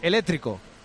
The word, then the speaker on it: eléctrico: Aquí se dice eléctrico. Eléctrico, vale. Técnico, helicóptero y espectáculo. – Vale. – Okay. Aquí la C hay que eliminarla.